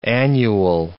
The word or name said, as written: annual